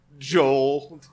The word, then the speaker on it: gaol: She traveled across the country visiting conscientious objectors in jail, or as the English spell it, goal. – What? – G-A-O-L, because... – Joel! – Joel.